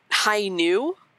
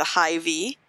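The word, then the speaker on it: Hainu (Hi-ν): The Gundam Hainu looks like the letter V in English. So some people who aren't familiar with Gundam might be calling it the High V.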